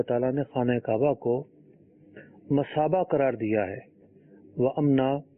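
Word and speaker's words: مثابة: یہ لفظ مشابہ نہیں ہے، مصابہ ہے. سے غلطی سے جو ہے وہ اس کا تلفظ شین پڑا گیا.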